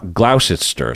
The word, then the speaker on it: Gloucester: So Gloucester is probably how it's pronounced, right? – Gloucester. – Gloucester? Gloucester. – Gloucester. – Gloucester. Like Worcester, right? – Yeah.